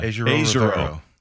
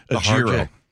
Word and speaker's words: Ejiro: Ejiro. Because he put a little harder. It was a harder J. He said Ejiro. – No yogging. – It wasn't a soft J. – Yeah, no, harder J there.